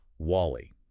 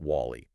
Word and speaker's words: WALL-E: He stubbornly insisted on calling it Wally, making it rhyme with Sally. – Wally like Sally.